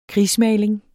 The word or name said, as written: krigsmaling